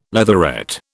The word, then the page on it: leatherette: British English